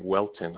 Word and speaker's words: Whelton: First name Joseph, last name Welton.